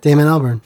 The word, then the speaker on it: Damon Albarn: All right, well, we're going to end with this De La Soul song with Damon Albarn. How do you say that? – Yeah, Damon Albarn.